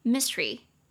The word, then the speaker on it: mystery: 但是其实这个字啊，我们在念的时候，我不知道大家刚刚有没有发现，当你念的比较快的时候，它其实会变成两个音节，会变成 mystery mystery，它的发音会变成 mystery mystery。这样两个音节，这个它会把中间那个 st 的的呃省掉啦。